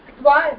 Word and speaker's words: tva: twa ik it twa twa now we should be pronouncing that Tva is a pratyaya.